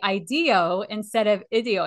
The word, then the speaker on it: IDEO: I am saying, because I always wanted to say, I want to say IDEO because it makes me think of ideas when I hear the word IDEO. – Yes. – Okay. – Yes. – Yeah, so IDEO I am saying it right.